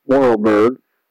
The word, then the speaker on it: Vorarlberg: the region of, I believe it's pronounced Vorarlberg.